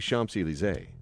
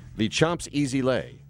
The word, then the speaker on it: Champs-Élysées: She didn't say Champs-Élysées, and I'm not going to say her name because she may be. – Was it Aaron Myers? – No, it wasn't. Oh, a friend of hers might tip her off to the fact that I'm bringing this back up again. But she said the Chomps Easy Lay.